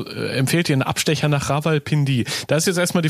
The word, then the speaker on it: Rawalpindi: Ja, war schon fast richtig. Rawalpindi ist, glaube ich, so ein bisschen schneller, ja.